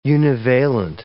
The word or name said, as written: univalent